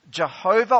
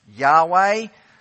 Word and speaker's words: Yahweh: This is the Lord Jehovah, or more likely pronounced Yahweh.